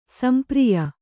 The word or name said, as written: ಸಂಪ್ರಿಯ